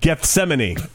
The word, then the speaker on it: Gethsemane: Gethsemane. Ding, ding, ding, ding, ding, ding, ding, ding. You get a new car. – I think it's just said. – No, it? Gethsemane.